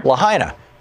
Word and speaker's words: Lahaina: Lahaina. – Yeah, used to live in Hawaii. She was just pronouncing it correctly for me.